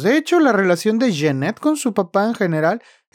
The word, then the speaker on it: Jennette: Jeanette, porque se pronuncia Jeanette,